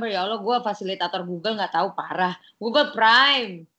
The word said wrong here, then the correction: Google Primer: bacanya Google Primer, ya, ya, ya, benar, ya, benar, benar.